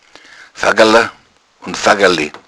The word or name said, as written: faggala